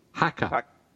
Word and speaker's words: haka: But anyways, so I was thinking, this hacker, I'm saying that wrong, right? – The haku? Haka. Haka. Haka. – Haka? Haka? – No, not said in Japanese. Haka.